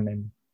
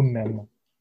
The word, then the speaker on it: mème: Je vois un mime. Comment dire mime en, français, on dit un, mème. Un mème. – Même? – Comme le mot même. c'est la même prononciation c'est même.